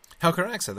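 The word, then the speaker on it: Helcaraxë: Now, as to their preparedness for the Helcaraxa, how do you pronounce that? – Helcaraxa, that's just right.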